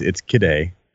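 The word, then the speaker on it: Kidde: Are you just saying Kidday so you can sound fancy? – There's no I after the D, so it's Kidday. I think that's how the company pronounces it themselves. I used to call them Kitty.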